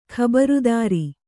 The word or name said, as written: ಖಬರದಾರಿ